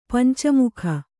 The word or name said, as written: ಪಂಚಮುಖಿ